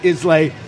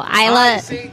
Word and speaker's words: Islay: You've got the Highlands, the Islay, and the Lowlands. Yep. When we talk about scotches. And Islay, I pronounced it Islay for forever and a day. I was wrong. It's pronounced the Isla.